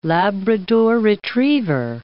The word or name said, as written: Labrador retriever